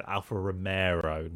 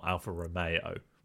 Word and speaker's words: Alfa Romeo: because she mispronounced it. Obviously, she said Alfa Romero, not Alfa Romeo.